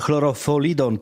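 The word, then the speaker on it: chlortalidon: Hilforeidlidon to substancja, lek na nadciśnienie, która podobno nie wpływa na piłkarza, ale właśnie chlorofolidon, tak się to dokładnie wymawia, zostało znalezione w organizmie Bartosza Salamona.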